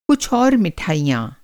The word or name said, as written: मिठाई